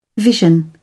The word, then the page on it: vision: British English